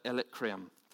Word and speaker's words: Illyricum: In this way, I have fully presented the good news of Christ from Jerusalem all the way to Illyricum.